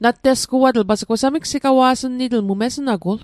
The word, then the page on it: nattesguatl: Mi'gmaq